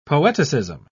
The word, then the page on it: poeticism: British English